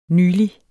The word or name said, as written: nylig